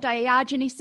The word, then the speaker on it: Diogenes: And when you were talking about Diogenes syndrome, did I say that right? – Yeah.